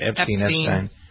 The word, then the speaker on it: Epstein: Epstein.